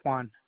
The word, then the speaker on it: Juan: Hey, my name is Juan, the technician. I have an order number for you. – Okay. What did you say your name was? – My name is Juan, J-U-A-N.